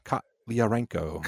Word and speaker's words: Kotliarenko: Directed by Eugene Kotliarenko. – Yeah. – Is that how you say it? – Kotliarenko. – Oh, I'm so sorry.